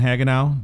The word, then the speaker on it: Hagenow: Thank you again, Dan Hagenow.